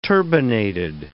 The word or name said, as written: turbinated